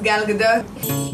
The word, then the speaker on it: Gadot: That's the way you say her name. – Hello, my name is Gal Gadot.